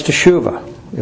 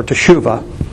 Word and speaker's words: teshuvah: And the word is teshuvah, if we're pronouncing it right.